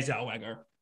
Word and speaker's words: Zellweger: So did Renee Zellweger. I think I'm saying her name right. – Yeah, Renee Zellweger.